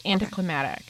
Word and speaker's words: anticlimactic: Is that how you say it? You say anticlimactic or is it anticlimactic? Climatic? – That sounds gross. I don't know. Whatever it is you're saying, it doesn't sound right. It's anticlimactic.